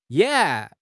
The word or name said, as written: Ye3